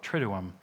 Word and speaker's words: Triduum: Traditionally, that is called the Triduum. It's an incredibly complicated word. There's two U's in a row, and you got to pronounce them both, Triduum.